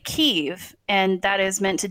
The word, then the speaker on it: Kyiv: that it's Kyiv, not Kiev. Kiev is the Russian pronunciation, so we should call it Kyiv